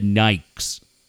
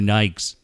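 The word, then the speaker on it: Nike: Now, we said Nikes, like rhyme with Mike. We didn't call them Nikes. We called them Nikes.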